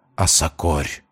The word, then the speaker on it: Осокорь: Осокорь!» Я невольно и сам начинаю повторять это незнакомое слово с ударением на последний слог. И сам не замечаю, как обретаю плоть, и слово это срывается с дрожащих, занемевших от мороза губ. «Осокорь!